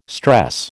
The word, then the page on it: stress: American English